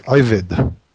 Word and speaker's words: Ebed: So it's pronounced Ivid.